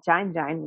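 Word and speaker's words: Kyain: And this Burmese place called Jain Jain, which is spelled K-Y-A-I-N, K-Y-A-I-N. I was taken there by a friend named Omar, and he loves that place.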